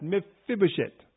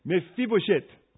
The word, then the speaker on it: Mephibosheth: Try that, Mephibosheth. Come on now, this is Hebrew, you cannot say it like that. Mephibosheth.